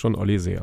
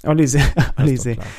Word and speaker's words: Olise: Michael Olise wird da, glaube ich, in England ausgesprochen, aber jetzt In der Bundesliga sprechen wir schon Olise aus. – Olise.